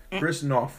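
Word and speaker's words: Chris Noth: Let me pronounce that right. No R. Chris North from Law and Order, Criminal Intent, and Sex in the City is turning 67.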